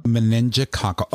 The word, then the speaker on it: meningococcal: Meningococcal.